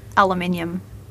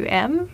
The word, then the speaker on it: aluminium: So the relevant thing is that in Canadian English, we say aluminum, but most Canadian spelling guides recommend to spell it as if it's aluminium. – Ah. – Because we inherited the British spelling with the American pronunciation.